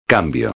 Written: cambio

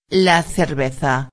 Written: la cerveza

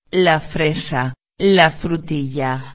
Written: la fresa la frutilla

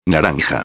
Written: naranja

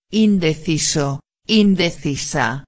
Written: indeciso-a

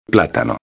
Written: platano

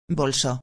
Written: bolso

